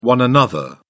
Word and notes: one-another: British English

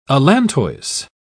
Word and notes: allantois: British English

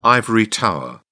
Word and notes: ivory-tower: British English

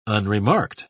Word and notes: unremarked: British English

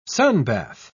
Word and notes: sunbath: British English

